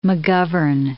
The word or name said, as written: McGovern